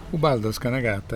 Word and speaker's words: Ubaldo Scanagatta: Ubaldo Scanagatta is the right pronunciation. You pronounce it pretty well.